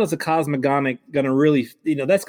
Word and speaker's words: cosmogonic: Am I even pronouncing that close to correct? – Well, you're close to correct. I mean, I only know because I listen audiobook instead of reading it, but it's like the cosmogonic cycle.